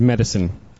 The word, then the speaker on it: Medici: You have to think of the Medici, when you're saying their name, as with the word medicine. It's like that. It's Medici.